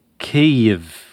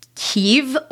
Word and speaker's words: Kyiv: So we're going with the Ukrainian pronunciation, or at least an approximation of the way Ukrainians might themselves say it. But a lot of Americans may be more familiar with the pronunciation of Kyiv, which is actually closer to the Russian pronunciation.